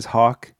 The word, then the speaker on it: Hawk: Yeah, so it's a hat that says Hawk, H-A-W-K. I do have to spell it because all my life people think it's H-O-C-K, or my last name is Hank, or you know, a number of things.